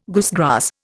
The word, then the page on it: goosegrass: Indian